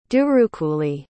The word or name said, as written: durukuli